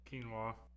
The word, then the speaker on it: quinoa: Go ahead and say it. – Quinoa. You're close.